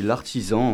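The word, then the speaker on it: l'artesan: Alors oui, c'est l'artisan, en fait. C'est écrit comme l'artisan, mais avec un E à la place du I. Et c'est le nom, la prononciation occitane.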